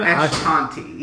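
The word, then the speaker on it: Eshkanti: Ashkanti. E-S-H-K-A-N. – E? Oh, okay. E-S-H-K-A-N-T-I. T-I. Ashkanti.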